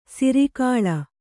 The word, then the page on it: ಸೂರೆಗೊಳ್: sūregoḷ